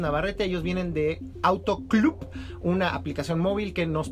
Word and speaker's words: Clupp: Así es, pues Club en primera, que se escribe C-L-U-P-P, con P P de, P de Pedro.